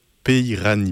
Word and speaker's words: Peirani: On en écoutera un petit extrait en fin d'interview de Vincent Periani et François Salk. Peirani, pardon, excusez-moi, j'ai écorché le nom.